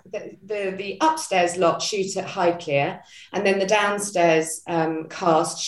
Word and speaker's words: Highclere: Highclere, yeah. – Yeah, Highclere.